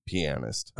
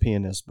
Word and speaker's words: pianist: which is a well-known other Louisiana old ragtime pianist. – Okay. – It's a different Pine Top. – I think it's pronounced pianist, by the way. – Yeah, it is a pianist.